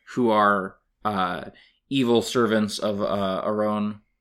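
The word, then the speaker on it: Arawn: It's A-R-A-W-N. – I have such a powerful memory of it being Aron. I'm looking at the pronunciation guide. – I think you'll find that it literally says Aron.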